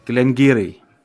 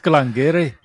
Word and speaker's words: Glengarry: Ich habe gerade extra nachgeguckt, nachdem ich ja beim letzten Mal so ein bisschen verbesserungsfähig war, die Distillerie schreibt sich, wenn ich es jetzt mal sehr deutsch vorlese, Glengarry wird ausgesprochen Glengarry. – Glengarry. – Yeah, that should be obvious, mate. That's Glengarry.